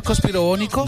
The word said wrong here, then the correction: conspiranoico: No, señor, conspiranoico. – Conspiranoico. – Se dice conspiranoico. – Pensé que era conspirobónico.